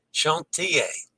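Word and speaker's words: Chantiers: Chantier. I have trouble with that for some reason, Dennis. Chantier. The final S in French is silent, as in Paris.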